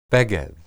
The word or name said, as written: beged